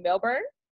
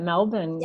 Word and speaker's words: Melbourne: Melbourne? – That's right. Melbourne.